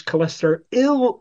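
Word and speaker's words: cholesteryl: If you listen to my pronunciations, cholesterol ill ester, YL, not OL.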